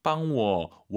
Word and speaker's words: 帮我: 빵워, 워 올라가면 안 되죠. 뒤에가 이성이니까. 워나, 이 발음 들려야 됩니다.